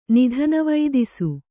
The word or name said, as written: ನಿಧನವೈದಿಸು